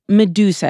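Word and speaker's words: Meduza: Medusa. Am I pronouncing that right? – Yes.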